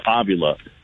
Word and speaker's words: Avila: I'm calling you Avila. That's your family's name. That's your dad's name. He said, yeah, man, that's how you're supposed to say my name.